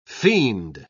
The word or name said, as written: fiend